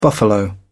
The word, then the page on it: buffalo: British English